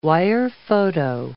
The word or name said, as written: wirephoto